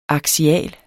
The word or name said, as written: aksial